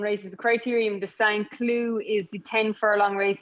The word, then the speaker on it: Saint-Cloud: Saint-Cloud is how you pronounce it. Saint-Cloud is how it would look like if you're reading the newspaper or checking any website. But Saint-Cloud, it is called, and he's got some big hopes there.